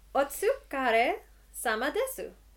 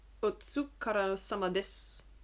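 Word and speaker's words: otsukara sama desu (お疲れ様です): Is committing a cardinal pronunciation sin in Japanese. Because it is otsukara sama desu. – Oh. – Because we don't pronounce that U no. – at the end there.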